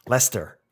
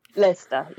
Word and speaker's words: Leicester: he taught me how to say it. I'm still scared of it. Leicester. – Leicester, yes.